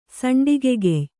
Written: ಸಂಡಿಗೆಗೆಯ್

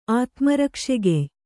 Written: ಆತ್ಮರಕ್ಷೆಗೆಯ್

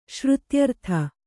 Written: ಶ್ರುತ್ಯರ್ಥ

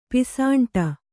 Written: ಪಿಸಾಂಟ

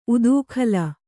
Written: ಉತ್ಕಲಿ

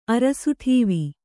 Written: ಅರಸುಠೀವಿ

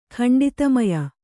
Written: ಖಂಡಿತಮಯ

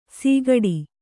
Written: ಸಿಗಡಿ

